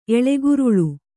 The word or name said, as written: ಎಳೆಗುರುಳು